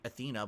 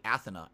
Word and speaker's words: Athena: Yes, that's how you pronounce them in Japanese. I know that you're Athena, but they say Athena, actually, over there.